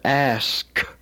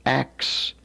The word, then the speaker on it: ask: Now, it's very important to understand that the consonant blend is pronounced in the proper sequence. For example, the word ask. Now, we know that there are a lot of people today who say ax me no questions.